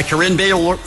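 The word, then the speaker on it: Corinne Bailey Rae: An unforgettable performance by Carine Bailey Ray. Boy, I sure can't say her name. – Oh, you sure can.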